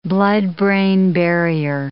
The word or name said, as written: blood-brain barrier